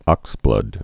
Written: oxblood red

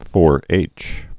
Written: 4-H Club

